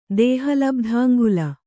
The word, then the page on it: ದೇಹಲಬ್ಧಾಂಗುಲ: dēhaalabdhāŋgula